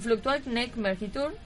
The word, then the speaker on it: Fluctuat Nec Mergitur: Ah, pienso que es en latín, porque ah, no es en francés. Ah. puede ser, serían Fluctuat Nec Mergitur que significa tocado pero no hundido.